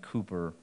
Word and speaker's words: Cowper: The English poet and hymn writer, William Cowper, it's spelled like Cowper, but everybody I could find was saying Cooper, so I'm going with it.